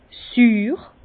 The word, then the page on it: süür: Bas-Rhin; Herrlisheim